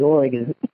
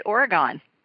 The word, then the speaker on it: Oregon: And then before I lived in Los Angeles, we used to say Oregon, and I learned how to say Oregon when I lived in L.A. – Yep, I say Oregon. – Yeah, you say it right. Yeah. But Yankees say it Oregon.